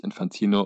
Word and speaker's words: Infantino: Aber er wird ja natürlich Infantino, unser geliebter Mann, ausgesprochen.